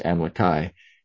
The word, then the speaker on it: Amlici: Amalickiah may imply son of Amlici, pronounced Amalekai, as Moronihah is the son of Moroni.